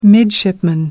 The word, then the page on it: midshipman: American English